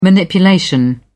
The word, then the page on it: manipulation: British English